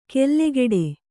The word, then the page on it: ಕೆಲ್ಲೆಗೆಡೆ: kellegeḍe